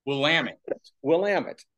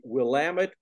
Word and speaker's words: Willamette: Dean, what you did, your accomplishment on swimming the entire length of the, and it's pronounced Willamette, right? – Willamette. Rhymes with damn it. – You swam the entire length of the Willamette River up in the Pacific Northwest,